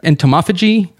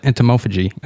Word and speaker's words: entomophagy: Then just recently, I was reading something on an entomophagy. I'm in medicine, yeah. And I can't even pronounce that. Entomophagy. Okay, there we go.